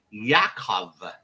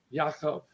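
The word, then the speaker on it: Yaakov (יעקב): The name James in Hebrew is pronounced Yaakov. – Yaakov. – Oh, Yaakov.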